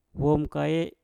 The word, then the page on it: wom kae: mpur